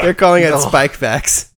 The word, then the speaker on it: Spikevax: Spike Vax.